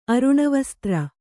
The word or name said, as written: ಅರುಣವಸ್ತ್ರ